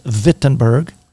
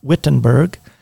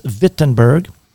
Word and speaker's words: Wittenberg: By the way, that is Wittenberg. – Wittenberg. – Yes. Some people mispronounce it as Wittenberg, but it's Wittenberg.